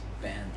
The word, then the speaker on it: Ben: And me and Bert would be like, you're mispronouncing my name. It's Ben.